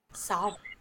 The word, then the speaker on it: Samhain: It's pronounced Samhain.